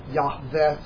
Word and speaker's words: Yahweh: Jehovah and Yahweh is the J would sound like a Y, and the W would sound like a V. Yahweh.